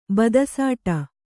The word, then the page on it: ಬದಸಾಟ: kannada